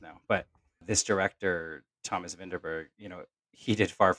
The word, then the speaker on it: Vinterberg: Oh, Vinterberg. That's how you say his name. – Yeah, Vinterberg.